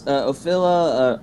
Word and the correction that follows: Ophelia: Ophila? – Wow. – Your name wrong. Ophelia? – You just fail at words. – Ophelia. That's right.